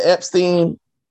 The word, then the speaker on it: Epstein: Is it Epstein? – Yep.